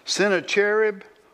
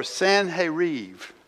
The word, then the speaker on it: Sennacherib: "So after these acts of faithfulness that we saw from Hezekiah, just read about, Sennacherib," or Sanherib, and that's the correct pronunciation, but I probably won't do that because I have it written down in my Bible one time. I'll probably just call it Sennacherib.